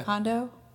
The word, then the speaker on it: Kondo: Kondo? – Yeah.